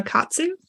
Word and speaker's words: Katsu: Um, I just finished The Deep by Alma Katsu. And mm-hmm. I have no idea if I just pronounced her last name right. Um, you did.